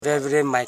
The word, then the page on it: veveremai: ve.ve.re.mai